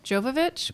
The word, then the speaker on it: Jovovich: Mila Jovovich, which by the way is three syllables, so my bad on that one, lost that bet, is an incredible actress.